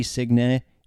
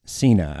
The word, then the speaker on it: Signe: And you might have heard the PA guy pronouncing her name with a G, Signe. It is Sina. I got the intel from the fellow Dane in the morning after practice, and I got it from Sina's mouth. Just think of John Cena after the game.